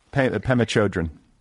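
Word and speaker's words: Pema Chödrön: Pema Chodron? – Pema Chodron.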